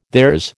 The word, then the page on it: theirs: American English